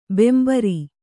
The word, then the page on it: ಬಾಂಬರು: Kannada